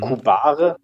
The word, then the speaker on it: cubare: also Kubare, das wären drei Silben, Ku, bar und Re. Mhm. Und das klingt, wenn man das jetzt mal einzeln sieht, wie das deutsche Wort Kuh, also die ja.